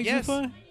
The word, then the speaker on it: Zuffa: Yes, Zuffa.